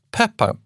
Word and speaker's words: Pepin: Pepin, P-E-P-I-N, så uttalas det på franska. Pipinus på latin.